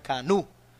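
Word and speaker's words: Kanú: But well, written in a Spanish way, K-A-N-U with an accent on the U. – Yeah, with a little... – Exactly. Yeah. So you pronounce Kanu. – Kanu. – Kanu. – Yeah. It almost sounds like Cuernu.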